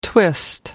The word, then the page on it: twist: American English